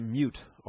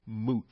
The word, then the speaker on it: mute: I used to pronounce it mute all the time. But a brother in Christ who's now with the Lord, I love very dearly, he was very insistent that the proper pronunciation is moot.